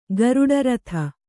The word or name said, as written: ಗರುಡರಥ